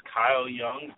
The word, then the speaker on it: Kyle Young: So, again, my name is Kyle Young.